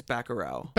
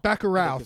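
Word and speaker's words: Bacurau: This is I'm going to butcher the name. Bakurau? – I think it's Bakurau. – Bakurau. Thank you.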